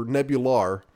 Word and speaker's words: Nebular: It looks like Rex Nebular, but in the game they do pronounce it with the voiceover Nebular.